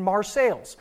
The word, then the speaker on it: Marseille: Marseilles is pronounced just like and gets its name from the French city Marseille. And if we know anything about the French, is they don't like to pronounce the last few letters of their words. And so, like, it's Marseille. Why?